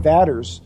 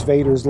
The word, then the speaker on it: Vaders: I was just at a conference in Missouri, and when they started it, they pronounced my name Vaders. So I let them know it was Vaders, like as in Darth.